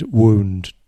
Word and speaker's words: wound: I said, "If you pronounce that word wound, W-O-U-N-D, wound, it's actually a completely different word."